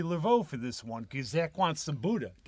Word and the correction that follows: boudin: didn't you? – Boudin. – Okay, Boudin. – I want Boudin balls. – Well, I pronounced it wrong, so she didn't give it to us.